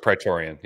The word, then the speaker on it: Praetorian: Praetorian, yeah.